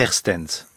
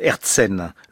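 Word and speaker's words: Aertsen: Pierre Erstens, je le prononce mal, Erzen, le Christ chez Marthe et Marie de 1552.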